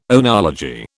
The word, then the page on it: oenology: British English